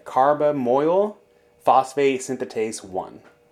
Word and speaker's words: carbamoyl phosphate synthetase 1: This ATP-dependent reaction is catalyzed by the enzyme carbonyl phosphatase, synthase 1. God, I fucked up that name. How do you pronounce that? Carbamoyl phosphate synthetase 1.